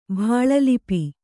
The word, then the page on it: ಭಾಳಲಿಪಿ: bhāḷa lipi